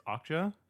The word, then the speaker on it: Okja: It's Okja. – Oh. – Is that how we're pronouncing that? – I think that's how they pronounce it in the movie. – Okja.